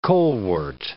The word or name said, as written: colewort